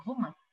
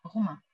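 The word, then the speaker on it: romã: Okay, en portugais, pardon, c'est romain. Romain. – Romain. – Romain, ouais. – Romain. – D'accord. – Et ça s'écrit comment? – R-O-M et l'accent til, A avec le til.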